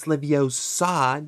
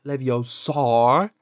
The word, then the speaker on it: Leviosa: Besides, you're saying it wrong. It's Leviosa, not Leviosar.